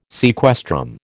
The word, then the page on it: sequestrum: American English